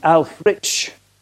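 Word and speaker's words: Ælfric: And you have the famous Alfric. They say he should be pronounced Alfrich.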